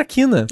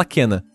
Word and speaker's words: Kena: É, Kena a pronúncia correta, porque eu, vou, eu vou aproveitar esse momento porque eu achava que era Kina. Eu também. E a gente falava Kina e as pessoas falavam Kina, mas no jogo é inconsistente porque cada pessoa fala de um jeito, mas a maioria fala Kena.